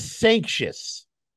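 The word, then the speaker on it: Sancious: I think it's Sanctious. And I've heard Patty say Sanctious, and I've heard it as Sanctious my whole life.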